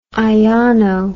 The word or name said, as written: iono